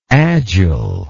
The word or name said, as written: agileness